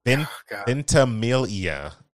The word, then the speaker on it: Ventimiglia: Ventimiglia is how you pronounce it according to Squiggles in chat. So Milo Ventimiglia.